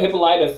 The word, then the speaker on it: Hippolyta: Every every time that her name came out, I was like, how the hell do you say her name? Like, hip, hypocrite? But no, no, Hippolyta.